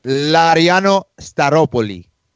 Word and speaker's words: Laureano Staropoli: Laureano Staropoli, and it's like he doesn't even emphasize any of the syllables in the last name, so you almost have to write the first syllable phonetically in caps, like Staropoli.